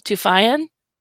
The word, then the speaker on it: Toufayan: Pronounce that company name for me because. – I can barely read it. – Toufayan. – That looks like a V to me. Toufayan. I don't know.